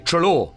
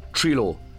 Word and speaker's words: Treloar: I think I say Treloar, but then people say what, and I say Treloar or Treloar. I don't know.